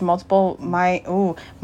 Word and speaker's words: multiple myeloma: She has been through the challenge of traumatically losing her father to fourth stage multiple myeloma.